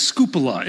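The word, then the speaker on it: skupala (σκύβαλα): That word garbage is a word that I've heard pronounced this way, as skupala. It's a Greek word.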